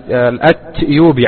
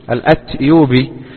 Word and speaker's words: الأتيوبي: ومن طرائف ما يروى أن شيخنا الشيخ العلامة محمد بن علي آدم الأتيوبي، عفوا كنت سأقول الأثيوبي، الأتيوبي، يكتب في كتاباته وفي كتبه بالتاء، بينما السائد في النطق أن الناس يقولون الأثيوبي له ولغيره.